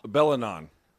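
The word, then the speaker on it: Belingon: Bellignon. The G is silent.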